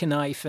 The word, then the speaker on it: knife: The only research I did on knife making, Tom, you better hurry, is that in old English, used to pronounce the K in all of the words that start with K, like knife and canoe and canoe and knight.